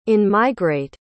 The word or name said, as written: in-migrate